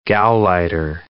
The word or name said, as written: gauleiter